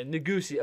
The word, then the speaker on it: Negussie: It's Negussie. N-E-G-U-S-S-I-E, that's how you say it. – Okay, Negussie, Abel Negussie.